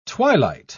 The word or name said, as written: twilight